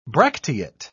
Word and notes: bracteate: British English

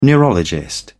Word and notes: neurologist: British English

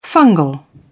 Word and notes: fungal: British English

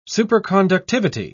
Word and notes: superconductivity: British English

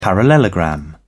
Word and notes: parallelogram: British English

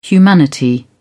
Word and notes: humanity: British English

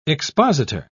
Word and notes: expositor: British English

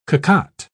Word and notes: cocotte: British English

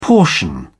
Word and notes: portion: British English

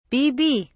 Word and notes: bb: British English

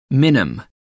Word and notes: minim: American English

